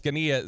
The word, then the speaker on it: Zgonina: If you are going to Google that, good luck because it is not the way that it is phonetically pronounced. Zgonina, Z G O N I N A.